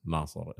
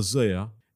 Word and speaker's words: Naser: And the first thing I said was, if we tour the UK or Europe, who's going to know how to pronounce Naser and Mestarihi? You know, they're going to be like, Naser sees me on stage and how's he going to pronounce the name?